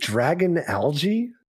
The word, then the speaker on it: Dragalge: Is it Dragon Algae? – It is.